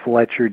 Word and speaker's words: Fletcher: It's my email address is Fletcher D, and I'll spell that.